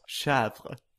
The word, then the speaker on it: chèvre: påpekar att det uttalas chèvre.